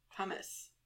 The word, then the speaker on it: pomace: I don't know how the accent goes on that. Pumice. Sounds just like the stone. – Mm-hmm. – But it's spelled P-O-M-A-C-E.